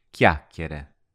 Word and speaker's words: chiacchiere: The pronunciation of chiacchiere, chats, can be a bit tricky at first. Let's break it down. There's a k followed by a y in the first two parts.